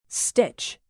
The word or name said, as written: stitch